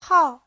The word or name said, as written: hao4